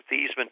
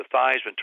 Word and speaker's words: Theismann: but we think by just simply changing the pronunciation of your last name from Theismann to Theismann to rhyme with Heisman, we can get you that trophy."